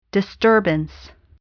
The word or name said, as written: disturbance